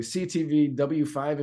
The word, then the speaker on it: CTV W5: I'm going to try to say this correctly. CTV W5 investigation. Is that the name? That's right. Yeah, yeah.